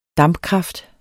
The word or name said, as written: dampkraft